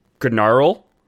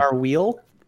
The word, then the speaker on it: Gnauril: Gnarl, the Elder. So I'm glad you brought up the G-N-A earlier, because this is spelled G-N-A-U-R-I-L. Gnarl the Elder was a contemporary... Go ahead. – Narwheel? – What's that? – I was like Narwheel? No, G-N-A-U-R-I-L.